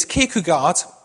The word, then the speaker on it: Kierkegaard: There's a Danish philosopher, my pronunciation is not very good, it's Kierkegaard, that's his name.